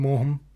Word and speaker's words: Maugham: Mr. Maugham, we're supposed to talk about the mom. – What? – Mr. Maugham, we're supposed to talk... – Maugham. Mr. Maugham.